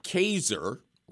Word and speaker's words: Kaser: Chuck Kaser, rhymes with laser, sends this week's missive from his Midori farm in Kyoto, Japan.